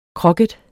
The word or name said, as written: kroket